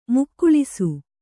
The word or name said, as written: ಮುಕ್ಕುಳಿಸು